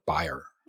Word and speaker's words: Bayer: Now, they all went to the Germans, a bear aspirin. Bayer. – Yeah. – We used to say bear. – We said bear. – Yeah, but now, but the pronunciation is like Bayer.